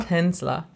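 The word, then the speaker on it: Tänzler: Oh, yeah, Tonsler? – Tonsler. – Oh, okay. – You get to repronounce all this stuff.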